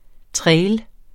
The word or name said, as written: trail